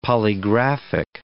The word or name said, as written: polygraphic